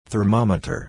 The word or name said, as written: thermometer